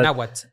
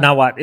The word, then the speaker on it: Nawat: and- so and I always say it, say it wrong. Na, Nawa, Nawa. It was yeah, the Nawat. Uh-huh. Nawat.